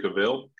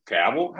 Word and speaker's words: Cavill: Cavill? How do you say his fucking name? – I'm looking this up.